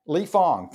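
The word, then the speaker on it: Lee Fang: Lee Fong.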